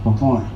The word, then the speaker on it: point: You don't pronounce the T. – Ah, pronounce the T. – Que votre cœur ne se trouble point.